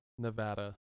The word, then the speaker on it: Nevada: Hold on. It's pronounced Nevada. – Oh, I apologize. Nevada. – No, it's okay. It's okay. – Okay. – Okay. You don't know. You're ignorant, but it's Nevada.